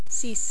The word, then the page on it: 6: French